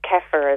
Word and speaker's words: kefir: Do you recommend kefir milk, asks a listener. – Yeah, it's fantastic. So also you can pronounce it kefir as well.